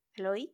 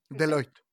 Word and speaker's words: Deloitte: O sea, el 61% de la población este, entrevistada en este estudio, que no, no, no sé cómo se dice, Deloitte. Deloitte. Deloitte. Deloitte.